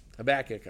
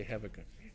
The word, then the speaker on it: Habakkuk: Habakkuk, I should say. I always say Habakkuk.